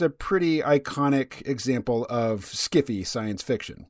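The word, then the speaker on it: sci-fi: There is sci fi, which author Harlan Ellison says should be pronounced skiffy.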